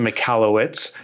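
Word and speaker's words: Michalowicz: So I pronounce it McAllowitz, like you said. The original pronunciation, I believe, was Mihailovich. It's from Eastern Europe, Ukrainian, Russian area.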